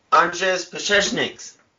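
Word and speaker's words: Anžejs Pasečņiks: The first being was, you're going to have to help me with the enunciation because I butcher his name every time I try to say this, is Anzens Pasechniks? – Anzens Pasechniks. – Thank you.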